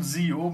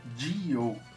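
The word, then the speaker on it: Zi-O: Eu estou falando Zio, mas a pronúncia é certa, Dio, né, em japonês.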